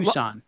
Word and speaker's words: Tucson: Tus- Tuscan Tus- Tuscan? What is that? Arizona. – Uh, Tucson. – Tucson. Oh wow. Yeah, the sea is silent.